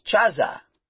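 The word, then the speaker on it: Chaza (חָזָה): And they often say that that word is Chezeh, C-H-A-Z-E-H in our alphabet. But there's another word they say is Chaza, C-H-A-Z-A-H. Spelled exactly the same way in the Hebrew. They give it a little bit, supposedly a little bit different pronunciation.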